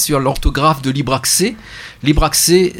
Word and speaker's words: Libracces: Libre access mais que l'on prononce libre accès.